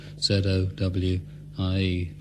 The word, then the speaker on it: Zowie: It's called Kooks and celebrates the birth of a baby on Sunday called Zoe at the moment, spelt, of course, Z-O-W-I-E, to rhyme with Bowie.